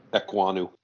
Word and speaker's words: Ekwonu: Well, how do you pronounce his name? Ekwonu? – Ekwonu.